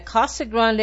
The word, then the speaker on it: Casa Grande: Casa Grande.